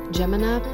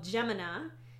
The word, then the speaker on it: Gemina: Gemina Part 2 from the Illuminae Files by Amy Kaufman and Jay Kristoff.